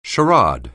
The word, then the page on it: charade: anglais